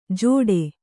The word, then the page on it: ಜೋಡೆ: jōḍe